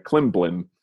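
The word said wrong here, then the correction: Klimbim: Klim Bim.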